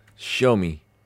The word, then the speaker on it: Xiaomi: Xiaomi.